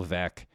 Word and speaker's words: Veeck: Like the more things you can do, this is where we need Bill Veeck, rhymes with wreck.